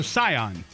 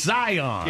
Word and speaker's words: Scion: And you said something about the Scion. No, Scion. It's, it's Scion. Yeah, it's it is spelled like the car.